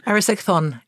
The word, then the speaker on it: Erysichthon: Erysichthon, yeah.